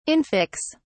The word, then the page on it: infix: British English